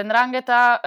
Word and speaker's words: 'Ndrangheta: So the 'Ndrangheta, and you pronounce it very well, congrats.